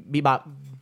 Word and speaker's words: Bebop: Bebop.